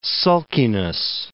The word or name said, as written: sulkiness